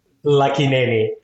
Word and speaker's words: Lakkineni: Lucky Nanny.